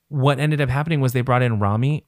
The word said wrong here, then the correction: Romy: I never know how to pronounce her name. Romy from the XX.